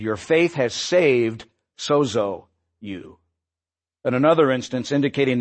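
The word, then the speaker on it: sozo (σώζω): Now, it's interesting that the word that's used in the original language that the Gospels were written, Greek, the word to heal or to make well, we would pronounce it sozo.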